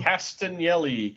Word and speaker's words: Castagnoli: Castagnoli.